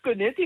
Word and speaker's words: koneti: En dan hoop ik dat ik het goed ga uitspreken. Koneti, Rosita. – Je hebt geoefend. – Yes! – Ja. Ja, dat is goed. Koneti is een goeie.